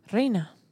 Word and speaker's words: Reina: So how do you say queen in Spanish, by the way? – Reina. – Grina? – Reina. – Reina? – Yeah.